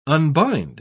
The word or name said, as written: unbind